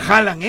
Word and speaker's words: Haaland: Y que sí se pronuncia Haaland, ¿eh? Haaland. Así me dijo. Te he escuchado en el camino y sí es Haaland.